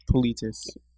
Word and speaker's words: Politis: Politis, I believe is how you say it.